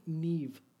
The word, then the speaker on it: Niamh: So his daughter's name is spelled N-I-A-M-H. – Neve.